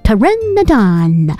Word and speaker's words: Pteranodon: 膀的哦，它长嘴巴张开啊，里面没有牙齿，然后呢它翅膀张开啊，是很大的翅膀的哦。这样类型的有翅膀的这种翼龙啊，无齿翼龙都通常都会叫它们是 Pteranodon，这个字长得很特别哦。如果有小小地球人看到，有可能会觉得不是很确定怎么念，因为它是 P T E R A N O D O N，所以呢 P T 一起就 P 没发声音就走 T，所以 Pteranodon 看起来有一点特别的，这个字很有趣耶。